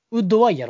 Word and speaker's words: WOODY: ウッディちゃうか。だってウッドの、ウッドワイやろ。 – あ、そうです、ウッドワイです。